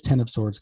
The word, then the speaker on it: X of Swords: It's Ten of Swords or X of Swords, however you want to pronounce it. But it is Ten of Swords because it comes from the tarot cards. And, you know, the ten of in a tarot deck is an X.